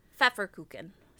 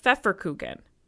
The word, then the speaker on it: Pfefferkuchen: Pfefferkuchen. – Pfefferkuchen, mm-hmm.